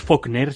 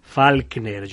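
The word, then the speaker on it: Falkner: John Mead Meade, se escribe Faulkner, que no es Faulkner, sino sin la U. Es decir, Faulkner,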